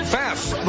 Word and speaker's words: Pfaff: Pfaff, the P is silent, but my God, the machine isn't.